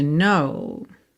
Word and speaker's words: know: The words will never show the you I've come to know. Então, I've come, você não pronuncia a letra E, tá pintadinha de cinza claro. No tem um K inicial que também não é pronunciado, certo?